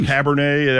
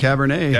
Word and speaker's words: cabernet: a piece of peanut butter pie with a nice cabaret. – Oh, good grief. – Or cabernet, that's how you say it. – Yeah, cabernet.